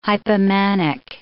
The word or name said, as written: hypomanic